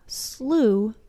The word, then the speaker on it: slough: Finally, we have the oddball oo pronunciation of the word through. There's only one word that matches, and that's the word slough, which means swamp.